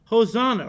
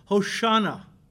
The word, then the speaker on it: Hosanna: Hosanna, Hosanna, or as the Jews pronounce it, Hoshana. Hoshana. We go over there and sing Hosanna and they laugh at us. It's Hoshana, Gentiles, not Hosanna.